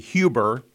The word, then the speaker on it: Huber: A lot of people pronounce it Huber. The Germans pronounce it H-U-B-E-R as Huber.